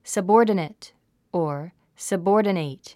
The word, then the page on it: subordinate: səbɔ́ːrdənət; サボーデネト